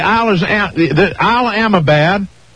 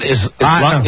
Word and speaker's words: Islamabad: Then you go to the Isle of Amabad. – It's not Alabama. – No, it's Isle Amabad, which is the capital of Pakistan.